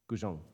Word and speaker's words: guzheng: Is it the guzeng from China? Guzheng. Okay, thank you and the harp from Egypt, and the ukulele from Hawaii, and the veena from India.